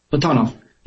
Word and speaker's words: Platonov: Platonov. Yeah, usually three syllables, the middle syllable is usually the one that's stressed.